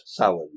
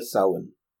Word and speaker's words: Samhain: Is that how that's pronounced? – Samhain, yes. Yeah, Samhain. – I always thought it was Samhain. – No, it's an Irish word, and it's Samhain, like the month.